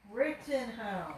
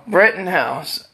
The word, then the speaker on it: Rittenhouse: And he claims he knows Breitenhouse's mother and doesn't know Breitenhouse's mother either. – Brittenhouse. – Thank you. Wife had to correct me on my, how do you say it? – Rittenhouse. – Rittenhouse.